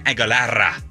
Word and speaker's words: Aguilera: There's a new Christina Aguilera, which is how you pronounce it, by the way.